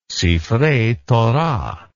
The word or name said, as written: sifrei torah